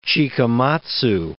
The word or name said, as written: Chikamatsu